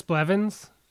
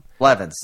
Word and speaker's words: Blevins: Did I screw up that name? – Yeah. Chris Blevins. Okay. Yeah, you messed that up. – Blevins.